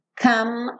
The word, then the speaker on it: come: Então, good times, come on, come on. Lembra que come, você não vai pronunciar o E, então não existe come, come on, ok?